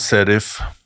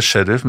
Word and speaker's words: Sheriff: Nå har du jo Serif Det uttales faktisk sånn, ikke Sheriff, men Serif Tiraspol.